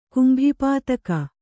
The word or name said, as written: ಕುಂಭಿಪಾತಕ